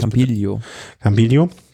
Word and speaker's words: Campiglio: Campiglio. Wie Campagnolo.